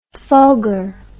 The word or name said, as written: fulgor